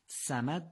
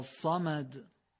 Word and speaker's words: صمد: اولین نکتهش تلفظ صحیح صاده، یعنی صادو مواظب باشیم که به سین تبدیل نشه. بنابراین صمد درسته یا صمد؟ دومی درسته.